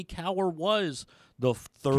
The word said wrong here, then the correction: Cowser: Cowser. Cowser, sorry.